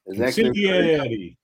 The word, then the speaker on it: consigliere: Well, I want to thank the edutainer, Consigliere. I say that right? – Consigliere.